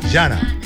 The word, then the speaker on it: Jhanna: Ella se llama Hanna. – No, Yana. Le hago la Yana, director. Yana. – Yana, con J. – O sea, se escribe J-H-A-N-N-A.